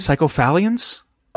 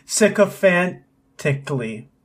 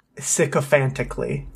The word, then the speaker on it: sycophantically: Psychophallians? Rallied an army. – Sycophantically.